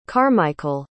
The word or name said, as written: carmichael